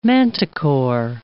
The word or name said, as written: manticore